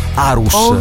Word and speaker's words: Aarhus: Aarhus.